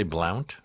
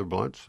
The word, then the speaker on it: Blount: People say Blount, and I can't really blame them. I mean, after all, if pronounce was pronounced the way my name is, it would be pronounced pronounce. And I wouldn't, and nobody would want to do that. So, but it's an old English pronunciation, and I don't want to change it just because.